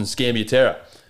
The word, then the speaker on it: Scambiaterra: Austyn Scambiaterra.